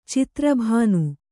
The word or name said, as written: ಚಿತ್ರಭಾನು